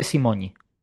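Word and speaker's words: Simonyi: El polaco me imagino que es eslávico, cuando ponen esa N con una Y es parecido a nuestra ñ, ¿no? – Yo no sé pronunciar polaco, pero a mí este nombre me parece húngaro. Y en húngaro sería efectivamente Simoni.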